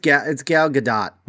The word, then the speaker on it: Gal Gadot: Yeah, because is it Gal or Gail Gadot? How do you say her first name? – Gal, I say. I don't know. Gal Gadot. – It's Gal Gadot.